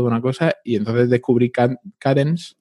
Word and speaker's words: Kadence: Ah, no, es Cadence. Cadence. Cadence. K-A-D-E-N-C-E.